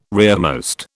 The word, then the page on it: rearmost: British English